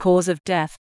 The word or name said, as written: cause of death